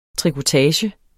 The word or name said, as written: trikotage